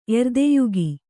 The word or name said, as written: ಎರ್ದೆಯುಗಿ